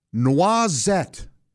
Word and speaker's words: noisette: Noisette. Noisette. It's spelled like noisette, N-O-I-S-E-T-T-E.